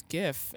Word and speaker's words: GIF: And you would click it, and it was created by the creator, and it would say, like, GIF, like the peanut butter, and then one of the other options was, no, it's GIF, and you would click that, and it would be like, no, fuck you, it's GIF, and, like, revert you back to the...